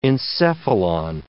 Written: encephalon

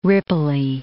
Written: ripply

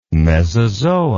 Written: mesozoa

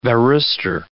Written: varistor

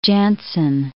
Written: Jansen

